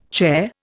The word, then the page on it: chair: Indian